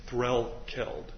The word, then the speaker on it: Threlkeld: That's Threlkeld. – Threlkeld. Threlkeld. Okay. – Just like it's spelled, all right.